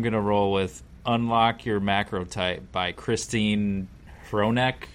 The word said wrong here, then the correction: Hronec: might be how you pronounce her last name. I don't know. It's Hronec.